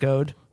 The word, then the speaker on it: Gode: The last name is spelled G-O-D-E. Would you pronounce that Goad? – That's Goad, yeah. Goad? I thought it was G-O-A-D, and this was a Jim Goad reference.